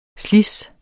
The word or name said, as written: slids